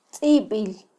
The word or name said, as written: tzibil